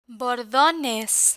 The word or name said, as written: bordones